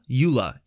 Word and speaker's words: Eula: Eula. E-U-L-A.